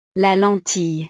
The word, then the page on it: la lentille: French